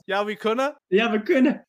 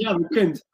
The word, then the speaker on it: ja we kunnen: How do you say yes? – Ja we kunnen. So it is almost ja we kunt.